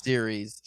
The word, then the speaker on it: Ceres: It's Ceres. I'm pretty sure it's Ceres.